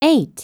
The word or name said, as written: 8